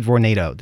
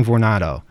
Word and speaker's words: Vornado: And you may have heard about this in the news as actually the battle between the Empire State Building and the apparently they pronounce it Vornado. Did you know that? We've been saying Vornado. I don't know. – Rhyme with tornado, I guess.